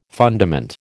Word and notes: fundament: American English

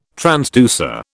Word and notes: transducer: British English

